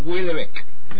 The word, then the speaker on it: Houellebecq: Houellebecq, más o menos.